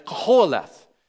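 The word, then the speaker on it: Qoheleth: Spit a lot. The more you spit, the probably closer you are to the proper pronunciation, but it's koholeth.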